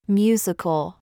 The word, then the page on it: ZOOS: American English